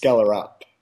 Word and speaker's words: Skjellerup: Skjellerup. – Yeah, it's all right except for the last part. It's Skjellerup. – So Skjellerup. Okay. – Yeah. – All right. – Not too much pronunciation on the UP.